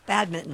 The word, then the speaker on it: badminton: No, we were talking about, I'm going to pronounce this incorrectly, badminton? You don't pronounce that N? – You do not. – No, badminton.